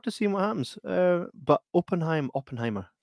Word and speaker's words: Oppenheimer: It's Oppenheimer.